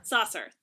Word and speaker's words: Sarsour: In their very first episode, they had Tamika Mallory and Linda Sarsour. I think is how it's pronounced. – Saucer. – Thank you. Linda Saucer. Apologies.